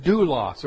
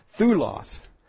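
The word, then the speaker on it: doulos (δοῦλος): In the Greek, we usually find the word doulos or thulos, modern pronunciation, thulos, the most common term for slaves.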